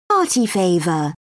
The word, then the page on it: party favor: British English